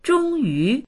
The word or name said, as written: zhong1yu2